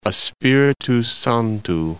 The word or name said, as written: Espiritu Santo